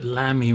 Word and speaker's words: Lamy: The first one is a German brand called Lamy, L-A-M-Y. I think I called it Lamy when I first came across it, but the German pronunciation is Lamy, I'm given to believe.